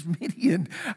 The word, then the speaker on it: Midian: What is Midian about? What is this a reference to? Well, Midian, which just to keep you thinking biblically, Midian echoes and kind of rhymes with Gideon.